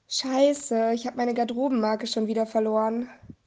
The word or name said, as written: Garderobenmarke